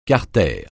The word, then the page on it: carter: francais